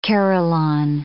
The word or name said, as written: carillon